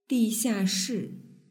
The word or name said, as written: dixiashi